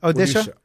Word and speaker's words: Odisha: Because you were saying that the place that you went, Odisha, is that, am I saying that right? – Yeah. – Odisha, which is kind of southeast central India versus Kerala, which is at the very tip.